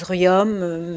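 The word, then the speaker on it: Riom: Vous dites Rion, ça s'écrit Rion. – Je ne sais pas, oui. On dit Rion, il y a des gens qui disent Rium, mais nous on a toujours dit Rion.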